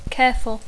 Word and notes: careful: English

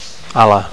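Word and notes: ala: English speaker

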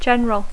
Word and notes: general: English

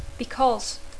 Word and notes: because: English

